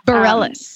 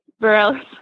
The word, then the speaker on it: Bareilles: I think that's how you say it. – Bareilles. – That was my song that I picked. Bareilles. – Yeah, Bareilles, Sarah Bareilles.